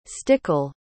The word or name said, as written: stickel